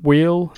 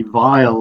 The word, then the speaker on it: Weil: but Fenton wheel, is it pronounced? – Well, in the UK, we always say Fenton wheel, but it's actually Vile, like Kurt Vile.